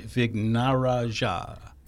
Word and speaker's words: Vignarajah: We are speaking of Krishanti Vignarajaj. The last name is Vignarajah, Vignaraja. – Vignaraja, I believe. – Vignaraja, where the J is a soft J.